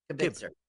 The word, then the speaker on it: kibitzer: Kibitzer. Like kibbles and bits, kibitzer.